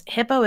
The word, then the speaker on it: Hippeaux: Hippo is spelled H-I-P-P-E-A-U-X.